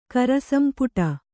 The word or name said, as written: ಕರಸಂಪುಟ